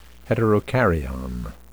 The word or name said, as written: heterokaryon